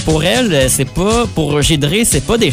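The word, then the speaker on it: Giedré: parce que ça s'écrit « Gidré », mais on prononce « Gidré », donc c'est « G-I-E-D-R-E » accent aigu. – G-I-E-D-R-E accent aigu.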